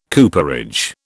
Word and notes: cooperage: British English

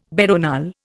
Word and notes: veronal: Indian

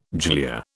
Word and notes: glia: British English

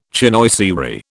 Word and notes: chinoiserie: British English

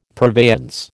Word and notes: purveyance: American English

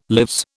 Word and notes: lives: British English

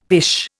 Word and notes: pish: Indian

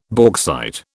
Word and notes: bauxite: British English

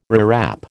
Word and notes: rewrap: American English

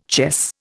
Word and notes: chess: Indian

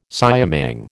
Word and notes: siamang: American English

